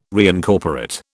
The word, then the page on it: reincorporate: British English